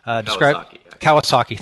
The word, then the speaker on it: Kawasaki: But one of them was, as a product manager, you'll have little to no actual authority, and you're quoting Guy Kawaski. I think that's how you pronounce his name. – Kawasaki, thank you.